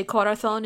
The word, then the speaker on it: corazón: Which is spelled C-O-R-A-Z-O-N, but you say corazón instead.